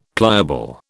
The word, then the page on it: pliable: British English